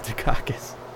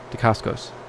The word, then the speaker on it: Dacascos: Not Dacascos. it's Dacascos.